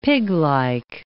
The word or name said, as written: piglike